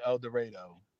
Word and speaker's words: El Dorado: There's a place in Arkansas that it clearly says El Dorado, and they pronounce it El Dorado.